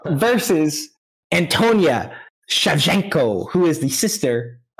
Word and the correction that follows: Antonina: What I think it's Antonina. – Yeah, Antonina. – Antonina.